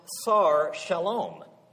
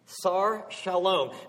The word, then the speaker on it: Sar Shalom: In Hebrew, it's pronounced Sar Shalom. Sar Shalom.